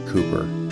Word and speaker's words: Cowper: Olney's other claim to fame is its association with the famous hymnists John Newton and William Cowper, C-O-W-P-E-R, but pronounced Cooper.